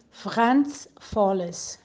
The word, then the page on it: Franz Volles: German